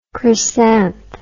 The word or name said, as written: chrysanth